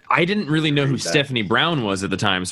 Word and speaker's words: Stephanie: Stephanie. – Oh my God. – Three syllables, dude.